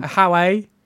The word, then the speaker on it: Haway: Just Google it. H-A-W-A-Y. – And how, then... How A? – Yeah, that's... – Is that how you say it? – That's, Is that what you want?